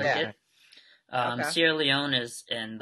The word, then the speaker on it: Sierra Leone: Sierra Leone.